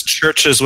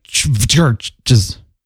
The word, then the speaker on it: Chvrches: By the way, folks, that's churches with a V instead of a U. CHVRCHES. Go ahead. – Exactly. So it'd be like churches. – Churches. It's spelled like old English.